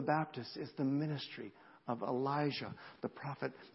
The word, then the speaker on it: Elijah: And his name was, I said it wrong in the bulletin this morning, I apologize, it's not Elisha, but Elijah.